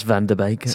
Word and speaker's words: Van der Beek: It's pronounced Van der Beek.